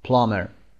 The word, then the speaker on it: plumber: کار plumber هست، بی تلفظ نمیشه plumber.